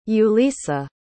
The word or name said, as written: yulisa